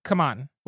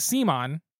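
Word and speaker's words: CMON: So Marvel United X-Men is published by Spin Master and Simon, which I actually found out they changed their name to, it's officially pronounced Kaman, which is really dumb. – Not in my house. – I think it's official, or it's, yeah, because we always called it Simon, because it was Cool Mini or not, and now I think it's Kaman, which is very, Simon sounds way better.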